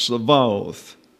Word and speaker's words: Sabaoth: However, when the holim is absent, indicated by the parenthesis, the pronunciation changes to a V. Therefore, the Hebrew title of the Lord is pronounced Sabaoth.